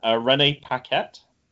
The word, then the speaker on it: Renee Paquette: Renee Paquette.